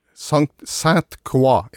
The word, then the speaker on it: Saint Croix: Ja, uh, si det en gang til, så skal jeg eventuelt korrigere det. – Sant Croix. – Saint Croix, ja. – Ja, var det Ja da, det er riktig uttalt.